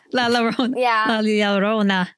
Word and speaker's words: La Llorona: La La How do you say that? Lorona? Whatever you say. – Now you're making me tongue tied. La Llorona.